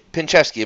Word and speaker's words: Pinchevsky: Carol Pinchevsky is Pinchevsky. Am I pronouncing that okay? – Absolutely, you are. It's pin like the needle, chef like the cook, and sky like the thing above you.